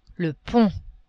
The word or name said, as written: pont